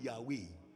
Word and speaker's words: Yahweh: So what the translators did was to add vowel sounds there, A and E, to make it sound Yahweh.